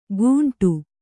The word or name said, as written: ಕುಂಟು